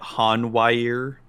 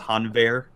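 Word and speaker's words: Hanweir: So I think so we've officially decided the official pronunciation for this card is Hanwear.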